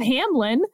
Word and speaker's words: Hamline: Hamline. Stop misspelling your school if you're going to call it Hamlin. But Hamline is Hamlin.